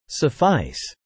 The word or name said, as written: suffice